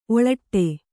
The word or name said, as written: ಓಲಾಡು